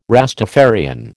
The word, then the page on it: Rastafarian: American English